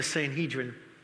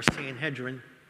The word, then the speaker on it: Sanhedrin: The council would have been the Sanhedrin, or Sanhedrin, could be pronounced either way.